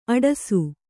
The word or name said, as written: ಅಟ್ಟಸ